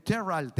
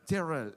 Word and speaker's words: Terrell: Y también para la gente que se dio cita, porque estuve con el chulo ahí en Texas, en el Silver Salón, en Terral, Texas. Así se pronunciaría, ¿no? Terrell.